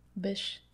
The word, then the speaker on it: Bysshe: Now, upon one of her visits home to London in 1812, she met Percy Bysshe Shelley. – Bysshe. – Still don't believe it's pronounced that way. That's how I've heard it pronounced so many times. I still don't believe it.